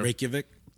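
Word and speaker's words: Reykjavík: Reykjavik? – Yes.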